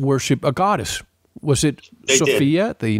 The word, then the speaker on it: Sophia: And you can call her Sophia if you like, but I call her Sophia because it rhymes with desire.